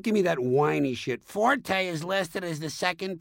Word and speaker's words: forte: The Italian word forte, F-O-R-T-E, used in music notation, is pronounced forte, and it instructs the musician to play loud.